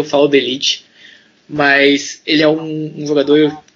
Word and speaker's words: De Ligt: Sobre a pronúncia do nome, eu sei que o correto é De Ligt, mas assim como Henry Chan, eu me recuso, então será De Ligt, enquanto esse jogador ainda não estiver no livro.